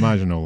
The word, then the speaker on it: Maginot: Yeah, like Maginot that's, line. – Maginot line. I didn't pronounce it right. I pronounce it almost like Italian or something, right?